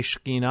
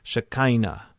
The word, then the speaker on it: Shekhinah: And if I say Shekhinah, I mean dwelling presence. If you come from a charismatic background, you might have heard Shekhinah pronounced as Shekinah. Shekinah.